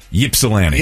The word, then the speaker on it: Ypsilanti: Listen, how you say it? – Ypsilanti. – Yeah, Ypsilanti. That's what it says.